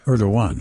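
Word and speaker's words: Erdoğan: The longtime, decade-long prime minister of Turkey, his name pronounced Erdogan. – Erdogan. – G is sort of a W sound.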